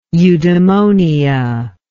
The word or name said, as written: eudemonia